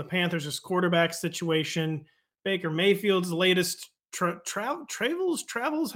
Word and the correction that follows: travails: How do you say that? I wrote a word in my intro that I forgot. – Travails. Travails. Tra- travails.